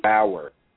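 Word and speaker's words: Baauer: And I still don't know how to pronounce his name. Bar. I think, is he Diamond? – Bauer? – Okay, thank you. Because you corrected me last time.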